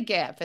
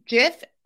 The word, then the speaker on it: GIF: GIF may be pronounced either as a soft G as in giant or a hard G as in graphic.